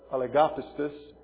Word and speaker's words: oligopistis: The phrase comes from the Greek word, the accent is on the G-O, the oligopistis, and it means literally little of faith.